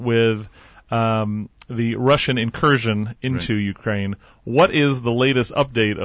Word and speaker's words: Ukraine: I do want to get into kind of Christmas and preparing for Christmas, and also how maybe in the Ukraine, in Ukraine, they do it on the second syllable.